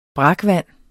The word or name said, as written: brakvand